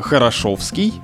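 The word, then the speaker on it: Хорошёвский: Ну, например, из-за того, что мы не всегда пишем букву «ё», возникают путаницы в таких названиях, как «Хорошовский» или «Хорошово-мневники».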